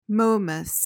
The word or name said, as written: momus